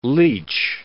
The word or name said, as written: leach